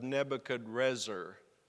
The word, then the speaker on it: Nebuchadrezzar: which was the eighteenth year of Nebuchadrezzar. That's that's spelled differently than Nebuchadnezzar, but it's the same person. There's an R there instead of the N.